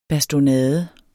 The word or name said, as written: bastonade